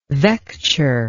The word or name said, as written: vecture